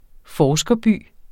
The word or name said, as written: forskerby